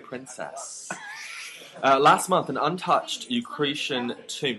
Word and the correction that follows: Etruscan: Did I say that right? Eucretian. – No. – Etruscan. – Yeah, I can't say that word.